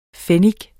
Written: pfennig

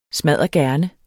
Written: Smaddergerne

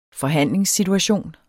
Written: forhandlingssituation

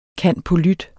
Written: cand.polyt.

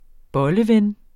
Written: bolleven

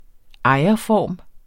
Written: ejerform

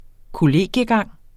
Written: kollegiegang

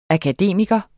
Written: akademiker

